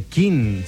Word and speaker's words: Keane: 97, Tom Chaplin a las voces, Tim Rice al piano y la batería Richard Hughes, se llaman King. La canción es bella como pocas, sin duda. Se llama Somewhere Only We Know y el grupo lleva por nombre King. Se escribe Keane con K.